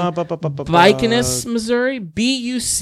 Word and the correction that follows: Bucyrus: It's not an N, that's an R. Bucyrus.